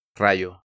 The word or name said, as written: Rayo